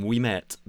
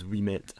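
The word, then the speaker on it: Ouimet: See, that's good French pronunciation, but the Americans called him Wimet because they just don't know what they're doing.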